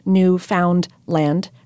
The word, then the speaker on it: Newfoundland: By the way, it's pronounced Newfoundland, rhyming with understand.